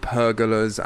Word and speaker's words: pergolas: There's an unusual method of growing the vines there on these sort of stone columns, these stone sort of pergolas and pergolas. Is that pronunciation, please? Pergolas. – Happy with that.